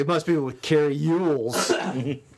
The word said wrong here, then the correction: Elwes: Yules. – Elwes.